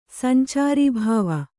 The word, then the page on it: ಸಂಚಾರೀಭಾವ: sancārī bhāva; kannada